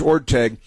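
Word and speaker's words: Orteig: the name is Orteg. The truth is, in the Spirit of St. Louis, the great movie classic Spirit of St. Louis with Jimmy Stewart as Charles Lindbergh, they also mispronounced the name Orteg in that movie with Jimmy Stewart, but here is being mispronounced last night on Jeopardy as well.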